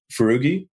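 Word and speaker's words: Foroughi: This guy, Adam, how do you say Adam's last name? – Furugi.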